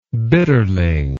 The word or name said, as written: bitterling